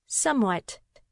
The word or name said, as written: somewhat